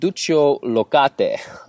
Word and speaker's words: Duccio Locati: Now, today I'm interviewing Duccio Locate on the topic of psyche.